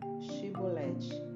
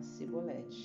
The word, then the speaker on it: Shibolete: Então eles pediam: Diga Shibolete. Mas ele sempre falava Cibolete, porque não conseguiam pronunciar corretamente.